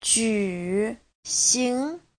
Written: ju3xing2

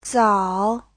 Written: zao3

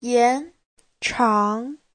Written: yan2chang2

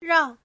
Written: rang4

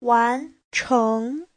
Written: wan2cheng2

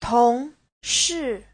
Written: tong2shi4

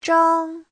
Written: zhang1

